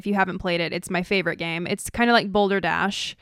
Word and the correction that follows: Balderdash: Balderdash. – Balderdash.